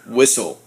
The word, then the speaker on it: Whissell: Whissell.